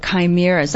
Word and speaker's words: chimera: Yes, last week we were talking about chimeras. That's spelled C-H-I-M-E-R-A. And it's pronounced chimera or chimera.